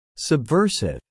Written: subversive

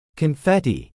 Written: confetti

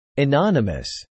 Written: anonymous

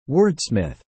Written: Wordsmith

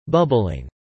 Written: bubbling

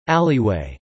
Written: alleyway

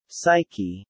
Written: psyche

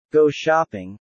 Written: go shopping

